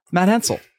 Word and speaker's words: Matt Hansell: A man with a beautiful mustache, Matt Hansell.